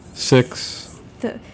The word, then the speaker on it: sixth: So, can you guys tell us about your new sixth CD? Did I say that right? – Six. – The TH at the end. – Yes, it is.